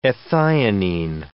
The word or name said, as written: ethionine